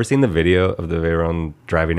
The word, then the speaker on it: Veyron: Is that how you say it? Veyron? Veyron? – Veyron? Yeah.